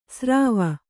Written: ಸ್ರಾವ

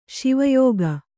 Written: ಶಿವಯೋಗ

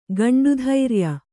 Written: ಗಂಡುಧೈರ್ಯ

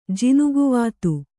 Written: ಜಿನುಗುವಾತು

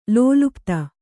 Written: ಲೋಲುಪ್ತಿ

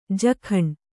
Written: ಜಕಂ

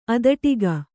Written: ಅದಟಿಗ

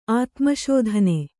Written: ಆತ್ಮಸಾಧನೆ

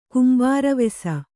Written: ಕುಂಬಾರವೆಸ

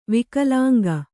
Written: ವಿಕಲಾಂಗ

